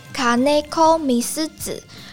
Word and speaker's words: 金子みすゞ: 他是活跃于二十世纪的日本童谣诗人金子美玲的日文念作卡内科米斯子，